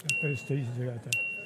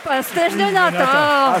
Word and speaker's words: pastéis de nata: Pastéis de nata.